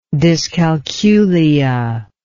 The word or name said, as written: dyscalculia